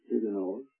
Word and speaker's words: Huguenots: There were the Huguenots, from France, sometimes called the Huguenots, but the French pronunciation is Huguenots.